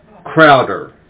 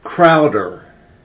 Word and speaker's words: Crowder: William Crowder. – Sorry? – William Crowder. – How do you spell that last name? – C-R-O-W-D-E-R.